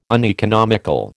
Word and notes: uneconomical: American English

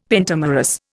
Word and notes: pentamerous: Indian

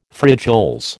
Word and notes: frijoles: American English

